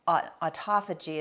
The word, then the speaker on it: autophagy: Uh, autophagy is how it's pronounced, but it's auto self phagy eat, eat self.